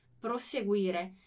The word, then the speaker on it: proseguire: Tá vendo que a gente não tem dois S's aqui, né? Prosseguire. Mas eu tenho essa pronúncia como se fossem dois S's.